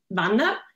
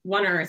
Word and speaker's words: Wanner: So in Swiss German, you would say Wanner. So in English, it's Wanner. So it's like a little bit strange how to pronounce Wanner. – It. – Yeah. Yeah, I yeah. may have said that, but W-A-N-N-E-R.